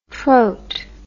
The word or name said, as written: troat